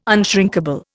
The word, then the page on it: unshrinkable: Indian